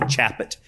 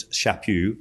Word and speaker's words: Chaput: Well, Archbishop Charles Chaput, that is his pronunciation, is hard. It looks like Chapit. It's Chaput of Philadelphia, says this.